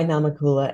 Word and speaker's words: Namakula: Well, actually, I go by Namakula, and Mu is actually the shortened version of my last name.